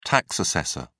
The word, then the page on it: tax assessor: British English